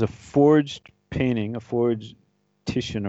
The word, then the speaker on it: Titian: It's it's Titian, is it? Or oh, Titian. You're right.